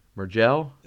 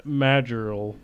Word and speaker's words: Majerle: Mergel? – Dan Majerl. – Majerl? – M-A-J-A-R... – M-A-J-E-R-L-E.